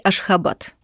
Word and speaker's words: Ашхабад: Но, опять же, в зависимости от того, на каком языке вы будете говорить, будет правильно произносить на том языке, на котором вы говорите. – Будем говорить Ашхабад.